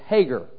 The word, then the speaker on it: Hagar: Okay, and I looked in a Bible dictionary. It's Hagar, okay, is how you pronounce it.